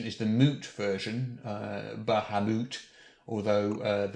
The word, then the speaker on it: Bahamut: the emphasis is on the middle bit, is the important thing, is Bahamut or Bahamat.